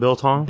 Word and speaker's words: biltong: So they pronounce it like Bitlong or Biltong. Like, I got you. They missed the T.